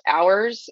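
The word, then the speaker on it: H8URS: Okay, and I watched videos from, I think it's pronounced Hours. It's spelled like H, the number 8, URS.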